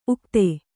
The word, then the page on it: ಉಕ್ಥ: kannada